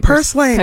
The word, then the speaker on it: purslane: Purslane, am I saying it right? – Purslane, yeah.